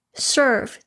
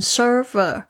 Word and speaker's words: serve: Is to serve, 就是去 serve 这个字呢是服务，大家特别注意一下，我的过去很多学生会念成 server，要特别注意一下后面这个 v 就停下来，因为它是动词。